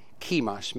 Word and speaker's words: Chemosh: Their head god, if you will, their superior god was Chemosh, or Chemosh maybe is a better pronunciation.